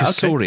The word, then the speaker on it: Cosori: Okay, so there's a company called Kazori, this is what you're talking about, C-O-S-O-R-I. – Yes, Kazori. That's why I didn't say the name, because I couldn't pronounce it. Kazori? – Yeah, Kazori, okay. I think that's how you pronounce it.